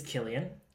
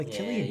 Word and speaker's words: Cillian: I'm not sure if the pronunciation is Cillian or Killian, but I think it's Killian. – Cillian. – I think it's Killian. – Yeah. – And just the fact that his name's Killian, I'm like, gotta watch your fellow Killian, you know?